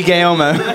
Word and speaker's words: Guillermo: That's why we got Guillermo to do the Q&A. – Tickle me, Guillermo. – You put an extra syllable in there. You're like Guillermo.